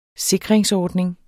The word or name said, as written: sikringsordning